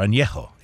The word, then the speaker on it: añejo: Anything that has rested, that's the tequila word, rested, for over 12 months is known as añejo. Or añejo, if you want to really try the pronunciation.